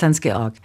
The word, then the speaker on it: Hans-Georg: Der arme Junge hat sich Zeit seines Lebens mit der Betonung auf der zweiten Silbe rumgeschlagen. Er heißt Hans-Georg.